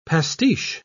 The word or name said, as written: pastiche